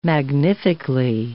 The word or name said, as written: magnifically